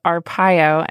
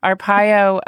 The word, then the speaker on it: Arpaio: We heard at the top of the show, Arpaio, and I'm changing my pronunciation very consciously. I realized I'd had it wrong all the time. Arpaio,